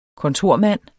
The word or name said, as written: kontormand